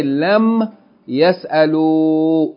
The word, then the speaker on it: lam yas'alu: So we say, lam yas'alu. When you do that, the noon from yas'aluna is deleted and the aleph is placed there. And that aleph is not pronounced.